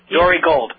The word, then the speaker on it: Dore: It's spelled D-O-R-E. I don't know if it's Dore or Dore. Gold. – Dore Gold. – Dore. Is it Dore?